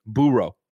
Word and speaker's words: burro: Ignacio Rojas writes in and says, burro is pronounced burro. – It's Spanish. That's what I said. I said burro. – I mean, he's correcting me, not you, not you. Because I was saying burro like a dog burrows.